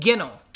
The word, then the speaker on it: ginnel: So the word this time is ginnel. I think that's how it's pronounced. – Ginnel. – Ginnel. G-I-N-N-E-L. – See, I think you are right in thinking that it's like fennel, but you're wrong. It's ginnel.